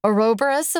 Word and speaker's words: Ouroboros: This one just felt to me like a Ouroboros of memory. Am I saying that right? Where you have a snake eating a tail here. – Yep, you got it.